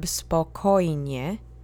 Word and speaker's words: spokojnie: And that turns into the adverb spokojnie. The accent moves to the second syllable, because it's always the penultimate syllable, or mostly.